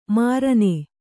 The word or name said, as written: ಮರಣ